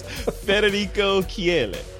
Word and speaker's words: Federico Chieli: His name is Federico Chieli.